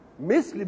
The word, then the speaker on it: مثل: كلمة مثل إذا سمعتها الميم والثاء واللام بتنطق كما يأتي، إما أن تنطقها مثل وإما أن تنطقها مثل. مثل بكسر الميم وسكون الساق مش كده، ومثل بفتح الميم وفتح الثاء. يبقى إيه الفرق بين مثل ومثل؟